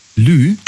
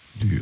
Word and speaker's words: lieu: Lieu. Also Lieu, das schreibt man, also L-I-E und dann U, Le Lieu. – Le Lieu. Lü, okay.